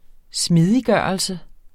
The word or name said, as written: smidiggørelse